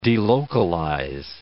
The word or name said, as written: delocalize